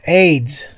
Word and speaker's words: Ades: A-D-E-S. Aides. – Aides? – Yes. – Nice. Just like the disease.